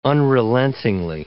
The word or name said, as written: unrelentingly